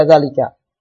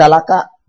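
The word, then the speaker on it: zalika (ذلك): Terus saja zalika. Ya, maka nanti ketika tidak ada harokat, nah, ya, jangan jadi zalaka, ya, nah, atau zalaki. Enggak. Kalau tulisnya seperti ini, pasti dibacanya apa? Zalika. Pasti kayak gini.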